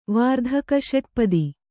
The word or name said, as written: ವಾರ್ಧಕಷಟ್ಪದಿ